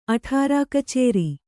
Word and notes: ಅಠಾರಾಕಛೇರಿ: aṭhārā kachēri

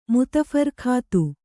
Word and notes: ಮುತ್‍ಫರ್ಖತ್: mut pharkhat

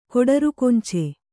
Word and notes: ಕೊಡರುಕೊಂಚೆ: koḍarukonce

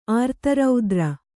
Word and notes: ಆರ್ತರೌದ್ರ: ārtaraudra

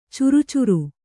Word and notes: ಚರ್ಚರಿ: carcari

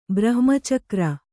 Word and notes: ಬ್ರಹ್ಮಚಕ್ರ: Kannada

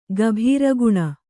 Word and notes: ಗಭೀರಗುಣ: gabhīra guṇa

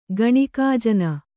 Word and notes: ಗಣಿಕಾಜನ: Kannada

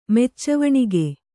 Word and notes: ಮೆಚ್ಚುವಣಿಗೆ: Kannada

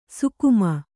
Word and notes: ಸುಗಮ: sugama

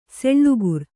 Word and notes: ಸೆಳ್ಳುಗುರು: Kannada

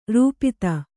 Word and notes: ರೂಪಿತ: rūp'ta

